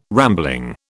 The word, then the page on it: rambling: British English